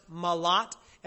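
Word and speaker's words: malat (מלט): The Hebrew word for escape is pronounced malat, and it's probably chosen on purpose to be a play on words.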